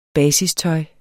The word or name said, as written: basistøj